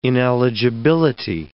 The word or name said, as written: ineligibility